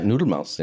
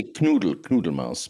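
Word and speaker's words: Knuddelmaus: Am I saying it correctly? – Yeah, Noodlemouse. Yeah. – Noodlemouse, which I think is like cuddle mouse. – Yeah, you could say Knoodlemouse.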